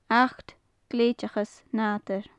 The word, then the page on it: achd gleidhteachas nadair: gaelic